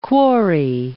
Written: quarry

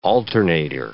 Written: alternator

